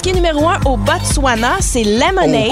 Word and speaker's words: Botswana: Je suis pas sûr qu'il se prononce, le thé. – Oui, il se prononce. – Le Botswana.